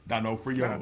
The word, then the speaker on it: D'Onofrio: Yeah, so it's D'Onofrio, not Donofrio.